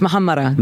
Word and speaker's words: Muhammara: Yes, Muhammara.